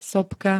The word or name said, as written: sopka